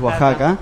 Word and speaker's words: Oaxaca: Por ejemplo, Oaxaca, yo siempre pronuncio mal, es Oaxaca.